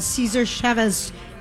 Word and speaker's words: Cesar Chavez: We are out on the road at El Burrito Mercado on Cesar Chavez. Is that how you say it, Melissa? – Yes, you said it perfectly.